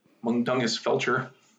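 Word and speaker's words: Mundungus Fletcher: Mundungus Felcher, and he... I know I'm saying that wrong, and he... – Pretty close.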